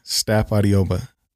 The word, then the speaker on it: Stapp-Adeogba: Adabi, Stapp-Adeogba.